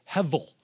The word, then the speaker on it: hevel: I said hevel. H-E-V-E-L, or you could spell it H-E-B-E-L, depending on your Hebrew pronunciation that you'd like.